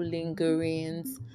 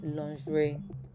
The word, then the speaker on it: lingerie: So a lot of people come on their WhatsApp status and say, okay, I sell beautiful lingerings and all that. But the right pronunciation is lingerie.